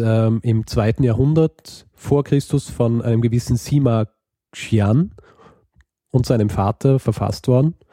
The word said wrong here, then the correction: Sima Qian: Ja, ich, wahrscheinlich habe ich es völlig falsch ausgesprochen, aber ich lehne mir jetzt aus dem Fenster und sage, heißt Sima Qian.